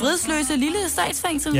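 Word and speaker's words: Vridsløselille Statsfængsel: Det er, hvis jeg udtaler det rigtigt, vridsløse lille statsfængsel. – Ja, det lyder meget rigtigt.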